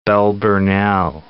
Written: Bell Burnell

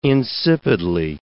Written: insipidly

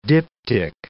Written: diptych

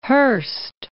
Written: Hearst

